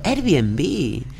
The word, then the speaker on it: Airbnb: Airbnb. Claro, se pronuncian dos veces la B.